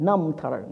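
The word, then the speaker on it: Namtar (རྣམ་ཐར): Silent affixed and prefixed letters. So it's the first consonant, the first syllable that could be a syllable in this combination of letters. So we put them in capitals. So it's spelled R-N-A, all right, M, but it's pronounced Namtar.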